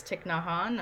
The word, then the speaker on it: Thich Nhat Hanh: About radical acceptance and this, another Buddhist monk she quotes, I think it's Thich Nhat Hanh, I'm not saying that right. – Yeah, who's, yeah, that's it.